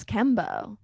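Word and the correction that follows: Kambo: It's actually pronounced Cambo.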